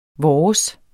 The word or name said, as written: vores